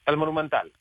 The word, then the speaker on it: El Monumental: El Monumental.